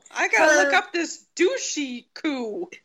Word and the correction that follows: Dushku: It's not douchey, it's douche coup.